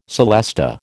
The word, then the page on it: celesta: American English